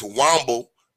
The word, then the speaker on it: Womble: And if you listen to the DA, Andrew Wobble, or Womble, whatever his name is, I know I can't talk or pronounce nothing, but I think it's Womble, W-O-M-B-L-E. If you listen to him, it don't matter if the car is stationary.